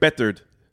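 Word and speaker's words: Beathard: I think it's Bethard. – Like feathered Bethard.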